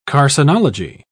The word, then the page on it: carcinology: British English